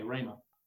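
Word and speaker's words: rhema (ῥῆμα): The word word is the Greek word rhema, transliterated R-H long E-M-A, rhema.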